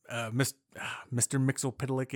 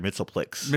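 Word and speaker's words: Mxyzptlk: See, I always pronounced it Mr. Mitzelplix. – Mr. Mixlpitlick. – Because that's how they did it in the Saturday morning cartoon.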